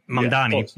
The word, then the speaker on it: Mamdani: Mamdani.